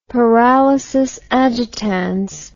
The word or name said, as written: paralysis agitans